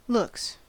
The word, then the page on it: looks: American English